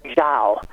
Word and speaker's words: Zhao: of her family name, which is Zhao, and that would be Zhao, J-O-W, should we say, in English.